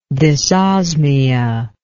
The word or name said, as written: dysosmia